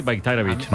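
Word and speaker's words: Bajraktarević: O sea, la J la conviertes en una I, Bayraktarevich.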